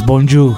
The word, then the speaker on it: bonjour: O sea, sí es muy parecido el saludo de Italia a Francia, pero se pronuncia de manera diferente. Es bonjour.